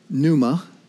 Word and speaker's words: pneuma: The word for spirit in Greek, pneuma, silent P, P-N-E-U-M-A.